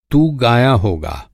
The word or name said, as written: तू